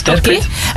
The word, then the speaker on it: Terquit: Jouw bedrijf heet Terquit, zeg ik het goed zo? – Ja, klopt. Terquit.